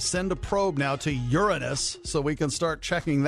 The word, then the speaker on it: Uranus: Everyone gets to bring out their inner fifth grader because the planet is pronounced Uranus. But we all know that most people like to say Uranus.